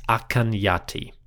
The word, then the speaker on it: Akhenaten: So when we write Akhenaten, we are writing the king's name in a more Middle Egyptian style. For the ancients, this man was probably known better as Akhen Yati.